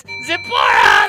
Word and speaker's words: Zipporah: Well, I guess I've been pronouncing it wrong all these years. I've got J. Vernon McGee's edition, and he's got accents on a different syllable. – Okay. – He's got Zipporah.